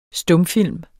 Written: STUMFILM

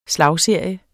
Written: slagserie

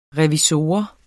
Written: revisor

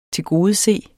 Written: tilgodese

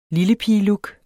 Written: lillepigelook

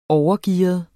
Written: overgearet

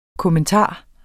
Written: kommentar

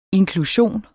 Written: inklusion

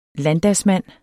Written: landdagsmand

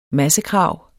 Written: massekrav

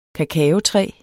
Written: kakaotræ

